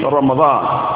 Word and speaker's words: Ramadan: To pronounce it with sukun on the letter Mim, which is Ramdan, is incorrect, like the people used to say. It's Ramdan, it's not Ramdan. Rather, it is Ramadan.